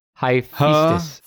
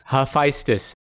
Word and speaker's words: Hephaestus: Hephaestus. Hephaestus.